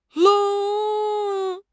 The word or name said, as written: 愣